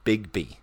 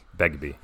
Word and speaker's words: Begbie: Yeah, Robert Carlyle as Bigby. – Begbie.